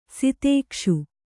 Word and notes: ಸಿತೇಕ್ಷು: kannada

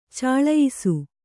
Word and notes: ಚಲಿಯಿಸು: caliyisu

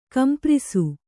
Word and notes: ಕಂಪ್ರಿಸು: kamprisu

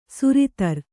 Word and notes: ಸುರಿತರ್: suritar; Kannada

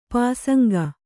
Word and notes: ಪಾಸಂಗ: pāsaŋga

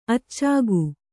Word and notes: ಅಚ್ಚುಗ: kannada

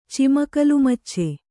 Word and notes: ಚಿಮಕಲುಮಚ್ಚೆ: kannada

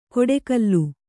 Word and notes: ಕೊಡೆಕಲ್ಲು: koḍe kallu

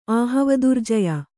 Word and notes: ಆಹವದುರ್ಜಯ: kannada

